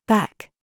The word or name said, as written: COOK